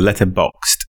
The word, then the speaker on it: Letterboxd: With emphasis on the D at the end, but no E. So Letterboxd, which enables you to catalog anything that you watch.